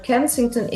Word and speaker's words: Kensington: Because the viewers probably don't know what Kensington is. What kind of area is that? – So Kensington is a neighborhood in North Philadelphia, the north side of Philadelphia.